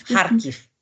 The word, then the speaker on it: Kharkiv: Und ähm, Kharkiv, spricht man das jetzt Harkiv oder anders aus? Harkiv, Harkiv. Harkiv ist richtig.